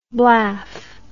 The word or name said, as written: blaff